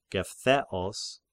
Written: Gephtheos

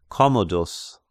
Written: Commodus

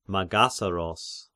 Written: Magassaros

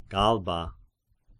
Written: Galba

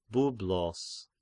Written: Byblos

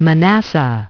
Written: Manasseh

